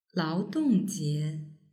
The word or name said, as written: laodongjie